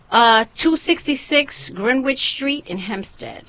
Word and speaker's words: Greenwich: Is that Greenwich? Is that how you say it? – Greenwich. Yes.